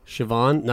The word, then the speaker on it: Siobhan: Yeah, yeah, yeah it's spelled right? like S H I O B H A N. – Yeah, you're like yeah, Siobhan. Like nope.